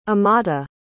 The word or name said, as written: amata